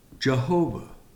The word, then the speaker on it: Jehovah: Pronounced in our language, Jehovah, or in the Hebrew, it's pronounced Yahweh.